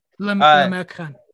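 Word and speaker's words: L'Americain: I do really like their scene in L'American. – Yep. – Am I saying that right? – L'American.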